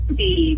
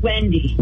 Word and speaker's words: Wendy: My name is Wendy. Rhymes with Ginny. – Did you say Judy? I'm sorry, you're going in and out a little bit. – I'm sorry, it's Wendy.